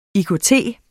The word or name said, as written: IKT